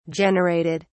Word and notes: generated: British English

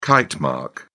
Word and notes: kitemark: British English

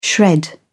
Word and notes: shred: British English

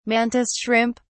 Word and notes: mantis shrimp: British English

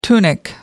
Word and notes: tunic: British English

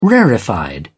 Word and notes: rarefied: British English

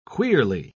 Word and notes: queerly: British English

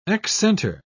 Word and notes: excenter: British English

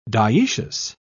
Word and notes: diecious: British English

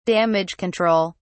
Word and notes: damage control: British English

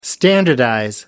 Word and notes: standardize: American English

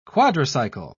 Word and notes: quadricycle: British English

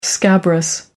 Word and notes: scabrous: American English

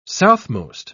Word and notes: southmost: British English

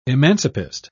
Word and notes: emancipist: British English

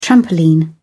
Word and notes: trampoline: British English

